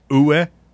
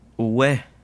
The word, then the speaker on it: Oweh: How do you say that last name? – Oh. – Uwe. – Wait, Jason what? – Uwe. – Uwe, O-W-E-H, little 6'5", 252 action.